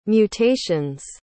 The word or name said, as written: mutations